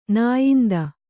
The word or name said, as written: ನಾಯಿಂದ